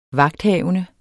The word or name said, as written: vagthavende